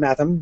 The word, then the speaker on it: Matham: For example, Bulger has a G as in bulge, and Matham rhymes with fathom.